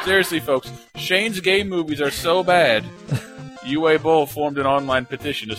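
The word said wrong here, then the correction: Uwe Boll: It's pronounced Uva Bull.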